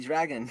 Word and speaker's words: dragón: Should we do an episode in Spanish, like "Donde está mi dragón"? – like Dora there. My God, that's so bad. – How do you say dragon in Spanish? El dragón.